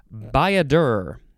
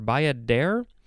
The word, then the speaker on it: bayadere: Now we have Bayadur. I think that's how it's pronounced Bayadur or bayadere.